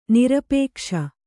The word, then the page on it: ನಿರಾಪೇಕ್ಷ: nirāpēkṣa